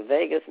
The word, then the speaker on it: vagus: But my friend Grace was telling me that there's a study being done on the vagus nerve, and it's spelled V-A-G-U-S as opposed to going to Las Vegas.